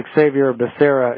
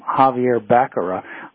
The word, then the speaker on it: Xavier Becerra: Yeah, and it's Xavier Becerra. And by the way, Biden didn't even know how to pronounce his name. He called him Javier Becerra when he announced it.